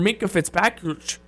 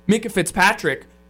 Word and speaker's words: Minkah Fitzpatrick: I think if he knew that the asking price or the given deal for Minkah Fitzpatrick, pardon me, I completely butchered that last name there, got my tongue twisted, Minkah Fitzpatrick, that's a no-brainer.